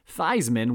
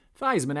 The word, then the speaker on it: Theismann: Theismann, which is actually in truth pronounced Theismann, but the All-American made a run at the 1970 Heisman Trophy, which is why the pronunciation of his last name was changed, kind of a marketing move to rhyme with Heisman.